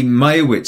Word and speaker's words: Meyerowitz: The Majewski stories, is that how you say it? – Oh, the Meyerowitz stories.